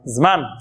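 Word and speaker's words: Zman (זמן): Zman.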